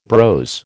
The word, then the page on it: brose: American English